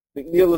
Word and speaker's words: McNeill: McNeill is spelled M-C-N-E-I-L-L.